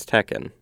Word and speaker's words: Tekken: Like if you mispronounce this word, we're gonna get you. But yeah, it's it's Tekken. It's Tekken.